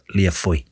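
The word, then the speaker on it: Lia Fáil: It's pronounced Leofail. So L-I-A-F-A-I-L. But I did go, I did break the habit of a lifetime and I looked up the pronunciation of something. – Oh my God. – I did. And it's pronounced Leofoy.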